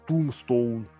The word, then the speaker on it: Tombstone: Tombstone правильно говорить без Б и без О, оно Tombstone.